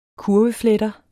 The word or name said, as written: kurvefletter